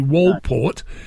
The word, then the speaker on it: Walport: And earlier this year, the government's new chief scientific advisor, Sir Mark Walport, is that, but that would be not Walpole, no, it's not a silent T, Walport, dismissed it as, you ready for this, quote, nonsense, unquote.